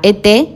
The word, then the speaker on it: et: La ET es exactamente eso. Entonces la T no la vamos a pronunciar.